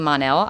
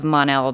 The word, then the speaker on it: Mon-El: Based on the origin of the name Mon-El, I would assume that the Mon was originally intended to be pronounced the same way as the first and penultimate. I see what you're doing there, Lynn. The penultimate syllable of the word Monday.